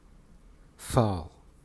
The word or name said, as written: fall